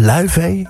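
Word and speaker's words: Laufey: Het was Luivee bij Sublime uit IJsland. Hoe zeg je dat nou? Luivee? Ja, zo spreek je het echt uit.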